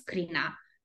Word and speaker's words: Crina: I was Crina.